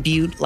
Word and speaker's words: Beute: Hit me up, Ethan Butte, last name is spelled B-E-U-T-E.